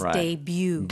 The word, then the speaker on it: debut: Well, the way Americans say this word is debut. – Debut. – Debut. But maybe the Korean is closer to the French.